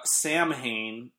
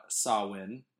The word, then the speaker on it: Samhain: So he says Samhain, when it's really, I actually have the pronunciation here. Samhain is how you're supposed to say that.